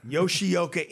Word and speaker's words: Yoshioka: Yoshioka in studio from Powerline Management.